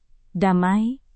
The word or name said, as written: damai